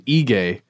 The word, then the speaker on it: Aegae: In a place called Ege. And again, the internet says I should pronounce it that way. It's spelled A-E-G-A-E.